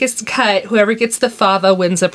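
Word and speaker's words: fève: But it always has a fava in it, which is F-E-V-E, but it's pronounced fava.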